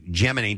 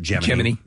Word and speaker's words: Gemini: And Aldrin was one of the two pilots on the Gemini 12. They pronounce it Gemini. Not Gemini.